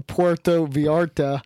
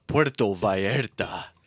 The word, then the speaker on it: Puerto Vallarta: But shout out to Dan in Puerto Vallarta. Is claiming that ass tastes better in Mexico. – You do Duolingo every day and you got terrible pronunciation. It's Puerto Vallarta.